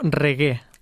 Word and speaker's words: reggae: Òbviament, el nom del reggaeton doncs, ve del nom reggae, però es pronuncia, això és molt curiós, no es pronuncia reggae o reggae, com li diu la gent, es diu reggae. – Reggae. – Aquesta és la pronunciació correcta d'aquest estil musical.